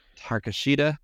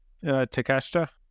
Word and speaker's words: Takeshita: William Ospreay versus Konosuke Takeshita. – Takeshita.